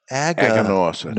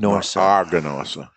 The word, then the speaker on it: Aganorsa: It's pronounced Aganorsa. – Aganorsa. – Aganorsa.